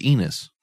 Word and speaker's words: Enos: And their name isn't Enos. – Nope, yes. Right. Yeah. Doesn't rhyme with penis.